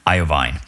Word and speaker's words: Iovine: Iovine. Thank you. Uh, rhymes with iodine.